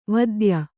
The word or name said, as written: ವಾತ್ಯೆ